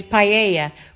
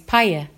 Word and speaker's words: paella: I used to thought it was paella, but it's not, it's paella.